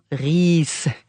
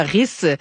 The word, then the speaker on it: Ries: L'ensemble Nash jouait le sextuor pour piano opus 142 de Ferdinand Ries. Et non pas Ries, j'ai un connaisseur de la langue allemande. Là. P.O., il connaissait beaucoup l'allemand. Merci d'avoir corrigé ma prononciation, P.O.